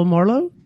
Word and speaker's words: Marlowe: I'm going to say it like you would say it, and we'll see if you had it right. So please welcome to the show, Phil Marlowe. – Yep, that's perfect.